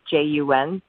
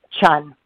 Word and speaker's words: Cheon: It's actually pronounced like J U N, Jun, but the ch I don't know the translation was a uh, was a little crazy. So I meet people halfway. Chun.